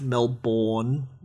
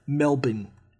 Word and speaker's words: Melbourne: It's not pronounced Melbourne, all those people from overseas. We pronounce it Melbourne. M-E-L-B-I-N, Melbourne.